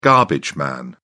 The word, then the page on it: garbage man: British English